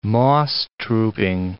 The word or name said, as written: moss-trooping